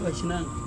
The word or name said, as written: aisinang